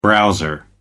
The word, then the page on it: browser: American English